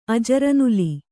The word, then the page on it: ಅಜರನುಲಿ: ajaranuli